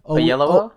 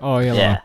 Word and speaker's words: Oyelowo: I forgot how to pronounce his name. – Oyelowo? – Yeah, that's it. Yep. – Oyelowo.